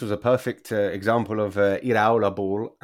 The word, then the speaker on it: Iraola: And sorry, I got the pronunciation wrong. Yeah, I knew you were going to correct me. You do that really well, actually, without saying to me, no, Mark, you got that wrong. You give me the proper pronunciation. I love it. – Good, yeah. Shall we practice it together? Iraola.